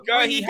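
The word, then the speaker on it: Guéhi: What's his name? Guy. – Gahi, hype man.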